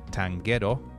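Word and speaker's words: Tanghetto: I was recently introduced to a group called Tanguero, you know, spelled like ghetto,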